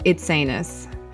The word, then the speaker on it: Itsines: Itsines.